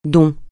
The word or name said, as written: don